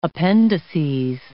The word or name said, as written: appendices